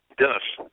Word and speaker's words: Dennis: Dennis, D-E-N-N-I-S.